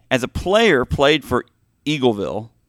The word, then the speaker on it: Eagleville: Jerry Armstrong is from Missouri, Eaglesville. Did I say that right? – Eagleville. – Eagleville.